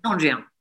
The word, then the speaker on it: Cendrillon: I loved what you did with Cendrillon. Is that how you say it? Cendrillon? – Cendrillon. – Cendrillon, apologies.